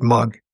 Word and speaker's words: Moug: Then, in the spring of the year, I had a guy that I knew, his Dale Mugg was his name, M-O-U-G, but he pronounced it Mugg.